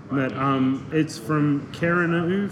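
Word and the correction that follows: Kererū: for anyone. – Oh, Kereru? – Kereru. Anyway, we never really knew how to pronounce it.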